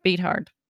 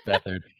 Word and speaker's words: Beathard: Beathard. That's not how you say it, but I know that's how it looks. – DJ Beathard. – Beathard. – It's Beathard.